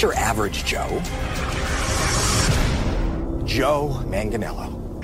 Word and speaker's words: Joe Manganiello: Joe Manganiello. – Joe Manganiello.